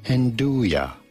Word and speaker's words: Nduja: In Italian, it is pronounced as Nduja. Nduja.